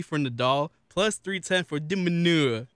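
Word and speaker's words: de Minaur: Demonor. – In the group stage of the ATP. – Is it manure? – Not manure.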